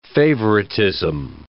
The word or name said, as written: favoritism